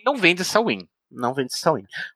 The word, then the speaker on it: Samhain: Que, que, se, que, que, se, fala, se escreve Samhain, S-A-M-H-I-A-N, uhum. só que se fala Samhain.